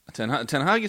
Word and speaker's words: Ten Hag: Tell us about Ten Hag. How will this work? And did I pronounce his name right? – Ten Hag is fine.